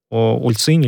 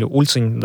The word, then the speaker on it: Ульцинь: И самое интересное, что вот Дритан Абазович, да, он как раз родом из этого города, Ульцинь или Ульцинь, да, тут ударение может меняться.